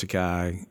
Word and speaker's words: Takei: Yeah, William Shatner and George Takei and rhymes with toupee, as he reminded Bill Shatner.